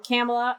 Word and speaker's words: Kamala: Like, and I think we've all said stuff like this before, but it was like, Kamala or Kamala, whatever the hell her name is or pronunciation is. – Camel toe. – Kamala. Yeah, it's Kamala. Thanks.